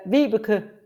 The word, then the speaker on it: Vibeke: How do you say it? – Yes. Vibeke Manicke. – Vibeke Manicke. – Well, you're very close, very close.